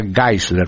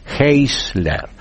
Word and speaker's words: Geisler: Un libro que se puede conseguir en Brasil, todavía no lo veo en español, el autor se llama Norman Geisler, se escribe G-E-I-S, Geisler, se pronuncia Geisler por lo que es en alemán, Norman Geisler con G, G-E-I-S-L-E-R, Geisler, así se pronunciaría en español, Geisler con G, pero se pronuncia Geisler, Norman Geisler.